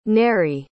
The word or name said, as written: nairi